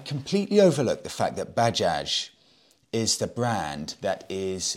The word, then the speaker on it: Bajaj: And it's not pronounced Baja because it is not a Spanish brand. It's pronounced Bajaj. And I'm hoping that's right. That may not even be right. But I had a lot of people pulling me up on this. Gorav said, it's pronounced Bajaj, like Bajaz, not Baha.